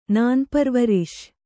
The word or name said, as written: ನಾನ್ಪರ್ವರಿಷ್